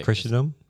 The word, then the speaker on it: Christendom: Christendom? – Yeah.